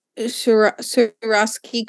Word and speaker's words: Swarovski: Swarovski Swarovski crystals.